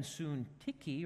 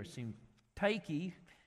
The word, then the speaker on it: Syntyche: Paul introduces us here to two women, Euodia and Syntyche, or Syntyche, depends how you pronounce it, but someone said, yeah, this is Odious and Syntyche.